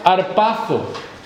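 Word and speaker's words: arpazo (ἁρπάζω): Esa palabra, arrebatado, es la traducción de una palabra griega que se pronuncia arpazo, Y digo cómo se pronuncia arpazo, porque es la palabra en la que nosotros hemos sacado el gesto de un oso cuando velozmente con su zarpa da un golpe y da un zarpazo.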